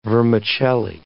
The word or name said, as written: vermicelli